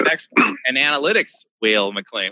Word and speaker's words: Weil-McClain: Is it Weil-McClain or Weil-McClain? – I say Weil-McClain. So if I've been saying it wrong, I've been saying it wrong for a while. – As I recall from my musical training, I think in German, the second syllable is the one that says its name. So I guess it would be Weil-McClain, like if it was W-I-E-L, it'd be wheel.